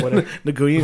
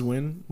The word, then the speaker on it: Nguyen: Nguyen. It looks like it's pronounced Nguyen, but it's Win.